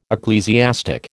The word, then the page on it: ecclesiastic: American English